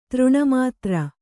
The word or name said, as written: ತಿರುನಾಮಧಾರಿ